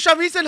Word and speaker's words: Shamisen: Und, und, und ich sage ihm, ja, Shamisen. Und dann kommt Shimasen. Shamisen! Okay, Shamisen heißt es.